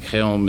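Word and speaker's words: Chełm: I think Chelm is the way it's pronounced in Polish.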